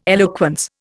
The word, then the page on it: eloquence: Indian